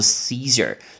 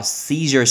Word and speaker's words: Caesar: 那个字 scissor，Caesar，Caesar 很像这个发音，凯撒就叫做 Caesar，所以凯撒沙拉叫 Caesar salad，Caesar salad，Caesar salad。